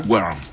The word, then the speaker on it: Guérin: Yo pienso que es Guérin, ¿verdad? – Guérin. – Algo así. Bueno, si algún francés nos está escuchando, que me corrija si lo dije bien.